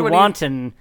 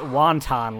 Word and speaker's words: wanton: I said wanton, but I pronounced it wonton, like the food.